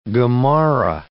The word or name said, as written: Gemara